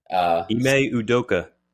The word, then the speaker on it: Ime Udoka: Ime Udoka.